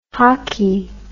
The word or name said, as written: hocky